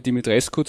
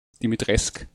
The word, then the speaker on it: Dimitrescu: Äh, auch der Name Dimitrescu zum Beispiel ist natürlich ein rumänischer Name, auch wenn sie es im Spiel dauernd französisch aussprechen, nämlich Dimitresk.